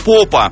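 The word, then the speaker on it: Popa: Вратарь, впервые вышедший на поле за сборную Румынии в 2008 году, его зовут Мариус Попа. Ударение фамилии именно на первый слог.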